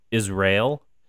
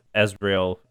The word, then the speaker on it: Azrael: Interestingly enough, I don't know if there's any correlation between this, but there's all, his name is also pronounced Israel, makes it kind of sound like Israel. Mm-hmm. And as Ezreal, those are the two pronunciations.